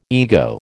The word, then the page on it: ego: American English